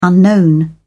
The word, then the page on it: unknown: British English